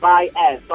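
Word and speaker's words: Baez: They think it's pronounced BYEZ, because it's B-A-E-Z. – Yeah. – They think it's BYEZ, but it's actually pronounced BYEZ, so I thought it would be clever to spell the podcast like the way it sounds, BYEZ, so BYEZ.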